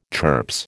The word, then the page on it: turps: American English